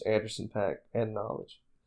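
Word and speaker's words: Knxwledge: And it's by Anderson .Paak and a producer named Knowledge, but it's spelled with an X instead of an O.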